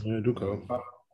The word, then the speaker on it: Udoka: I mean, it's Udoka.